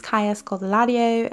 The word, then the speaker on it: Kaya Scodelario: And she's like, Hi, my name is Kaya Scodelario. And I was like, Oh, shoot, I've been saying it wrong the whole entire time. And the reason is because she's British and she's Brazilian. So she has a Brazilian uh, last name.